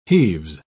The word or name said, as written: heaves